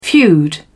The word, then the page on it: feud: British English